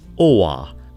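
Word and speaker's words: å: the end of the Swedish alphabet includes an A with a ring on top, pronounced Oa in the long version and O, as in lock in the short version.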